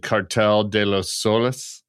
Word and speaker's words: Cartel de los Soles: which is the alleged Cartel de los Soles. I don't know if I'm saying that correctly. – Absolutely.